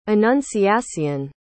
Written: anunciacion

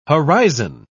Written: horizon

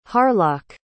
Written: harlock

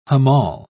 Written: hamal

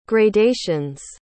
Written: gradations

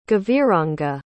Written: gaviranga

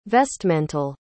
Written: vestmental

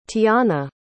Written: tiahna

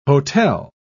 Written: hotel

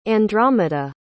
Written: Andromeda

